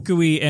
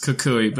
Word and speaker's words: Kukui: And Kukui and what's her face have a child. – We knew that. – First, it's pronounced Kukui, but second, just no.